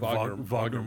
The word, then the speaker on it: Wagner: How do you say his name? – Like the composer. – Like Wagner Moura.